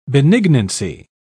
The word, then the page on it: benignancy: British English